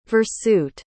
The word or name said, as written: versute